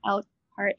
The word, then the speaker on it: Elkhart: So you grew up in Elkhart, Indiana, if I'm pronouncing that right.